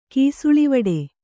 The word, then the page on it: ಕೀಸುಳಿವಡೆ: Kannada